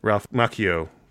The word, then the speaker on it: Macchio: Ralph Macchio, that's how you say his name.